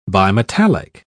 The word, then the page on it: bimetallic: British English